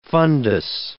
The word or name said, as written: fundus